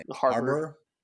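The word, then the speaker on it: Harbour: So I chose David Harbo, or is that how you pronounce it? I can never say his name. – Harbor.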